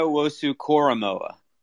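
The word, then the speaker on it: Owusu-Koramoah: What is it? Karamoa? – Yeah. Oh, you're getting closer, man. You're getting. Oh, yeah. It's Jeremiah Wosu-Koromoa.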